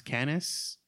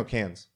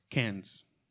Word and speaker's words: Cannes: The, I think it's pronounced Cannes Film Festival. – No, Cannes. – Cannes. That's right.